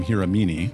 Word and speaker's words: Hiramine: White man pronounces your last name Hiramine. – Also Hiramine. – Hiramine? – Yes, right.